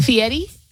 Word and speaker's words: Fieri: Fieri? – Yeah, it's just how you say it.